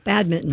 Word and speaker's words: badminton: You don't pronounce that N? – You do not. – No, badminton.